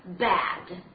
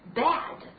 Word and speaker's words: bad: Now next one， beginning with B，我们来看一下这个单词 bad，啊，注意发音也是舌尾非常低，bad。